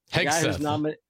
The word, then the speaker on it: Hegseth: Hegseth. – Yes, thank you. – Hegseth, yes.